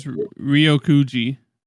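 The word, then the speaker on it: Ryokugyu: Ryokuyu? Is that how you pronounce that? – I think it's Ryokuji. – Ryokuji? Yeah, that sounds right.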